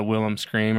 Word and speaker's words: Wilhelm: but it's always kind of like the Wilhelm scream or whatever.